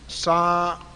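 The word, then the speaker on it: tsava: Now the word Sabbath is really a Greek transliteration of the Hebrew word Sa-va. Sometimes that V is spelled with a B, but it actually is more of a va, not a ba.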